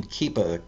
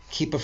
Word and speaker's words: Kepa: Uh, this one is from, I believe you pronounce this person's name Keepa, K E P A, Keepa Freeman.